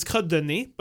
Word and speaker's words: Côte de Nez: It's Côte de Nez, but Nez is spelled N-E-Z. – Oh, okay. – And she pronounces the Z, but that's why.